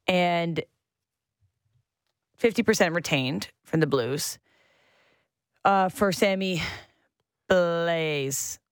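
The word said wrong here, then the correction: Blais: Sammy Blay.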